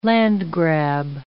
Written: landgrab

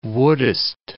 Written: wouldest

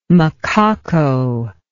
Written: macaco

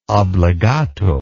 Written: obligato

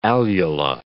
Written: alula

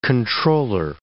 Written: comptroller

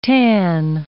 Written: tan

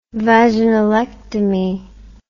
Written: vaginalectomy

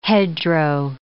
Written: hedgerow

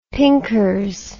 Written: pinkers

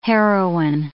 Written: heroine

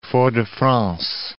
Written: Fort-de-France

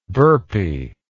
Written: burpy

